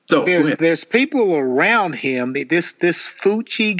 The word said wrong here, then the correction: Fauci: guy, the little short guy. Fauci. I can't think of how he pronounced his name. Yeah, Fauci. But he's good.